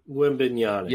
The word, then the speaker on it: Wembanyama: Am I saying his name right? – Wembanyama. – Yeah, Wembanyama.